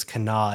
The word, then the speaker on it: Kanawha: Yeah, and if you're listening to this later on the podcast versions, Kana is spelled K-A-N-A-W-H-A, which is why I was pronouncing Kanawha, and had to be corrected when the show started and lost all of my cred in West Virginia, I think, on that one.